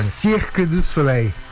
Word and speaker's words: Cirque du Soleil: Por favor, como é que, qual que é a pronúncia correta em francês, você que esteve lá durante muito tempo? Fala pertinho do microfone. – Cirque du Soleil.